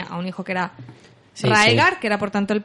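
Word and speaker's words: Rhaegar: Maynard pone que eh, anuló el eh, matrimonio del príncipe Rager en Dorn. ¿Qué está diciendo aquí? Gilly pronuncia mal el nombre Rager. – Hmm. En realidad está hablando del príncipe Raegar.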